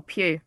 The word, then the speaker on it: Pugh: I'm not sure how to say her last name. Poe? – Oh, Pugh. – Pugh? Pugh. – Yeah. – Pugh, yeah. Makes sense.